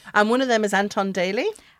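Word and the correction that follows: Danyluk: Dan Luck. – Oh, Dan Luck. Dan Luck. Okay. – Yes, I had to Google the pronunciation.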